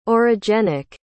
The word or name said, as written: orogenic